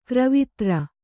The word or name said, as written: ಗಿರವಿದಾರ